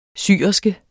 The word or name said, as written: syl